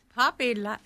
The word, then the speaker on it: Papi: I mean, it's Big Poppy, he's Latin. – Well, yeah, it is spelled P-A-P-I, Big Pappy.